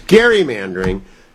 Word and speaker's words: gerrymandering: One is gerrymandering is routinely mispronounced. It's gerrymandering, but I'm a scold when I bring that up.